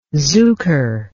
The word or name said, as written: zukor